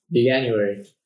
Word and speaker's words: Veganuary: Veganuary. January. Vegan. Yeah. Veganuary.